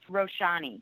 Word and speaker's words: Roshani: And one last thing I wanted to mention, I accidentally mispronounced the author's first name. It's Roshani.